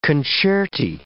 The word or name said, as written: concerti